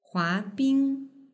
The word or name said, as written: huabing